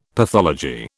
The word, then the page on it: pathology: British English